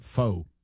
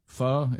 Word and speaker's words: pho: Pho? Is it pho? – I think you're supposed to say pho. – It's not pho. – It is spelled pho, but I think you're supposed to say pho.